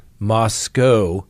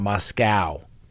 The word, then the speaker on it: Moscow: So apparently it's Moscow, not Moscow. We pronounce it like we see it.